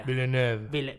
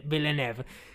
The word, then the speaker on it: Villeneuve: Villeneuve. – Villeneuve.